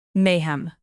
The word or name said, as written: mayhem